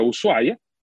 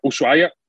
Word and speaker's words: Ushuaia: cuja capital é Ushuaia, é, que não se pronuncia Ushuaia, como muitas pessoas dizem, mas sim Ushuaia, porque o H é mudo e Ushuaia é uma palavra no idioma jagante, o idioma dos indígenas que existiam ali naquela, naquela região.